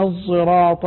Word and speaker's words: الصراط: حقيقة لا نأتي بالزاي بعد الصاد ولا نبدي بالزاي بدلا عن الصاد، هنا إشمام الصاد زايا كما يقول علماء لدى خلف، واشمم لخلاد الأول، عند خلف في كل وعند خلاد في الموضع الأول، ننطقها مشمة صاء زايا، يعني يقول يعني مثلا قراءة العامة يقول اهدنا الصراط المستقيم، اهدنا الصراط، خلف يقول اهدنا الصراط المستقيم، صاد لكنها فيها رائحة الزاي، صاد مشربة زايا